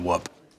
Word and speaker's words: whoop: But I actually don't pronounce the H in whoop.